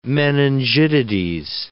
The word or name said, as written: meningitides